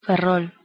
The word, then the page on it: Ferrol: Galician